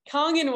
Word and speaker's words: Kangen: Yeah, it's Kangen water, which Kangen was, you know, that came into my realm, and then that took me down the watering hole.